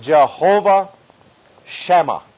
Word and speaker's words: Jehovah Shammah: This is a name for God in the Hebrew, which is pronounced Jehovah Shammah, and simply means the Lord is there.